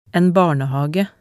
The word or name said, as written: en barnehage